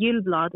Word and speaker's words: Gyllblad: So it's Jennie with an I-E, and Gyllblad is G-Y-L-L-B-L-A-D.